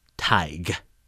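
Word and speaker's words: Tadhg: It's spelled T-A-D-H-G. I pronounced it Tad because I'm a dumb American. It's actually pronounced Tig.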